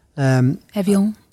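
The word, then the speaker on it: Évian: Evian, yeah. That's the French pronunciation.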